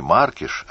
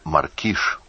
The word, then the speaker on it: Маркиш: Теперь уже не Маркиш, а Маркиш. Ударение сменилось. И не Симон, а Шимон.